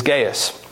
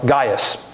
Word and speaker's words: Gaius: His name, depending on how you pronounce it, is Gaius, or Gaius.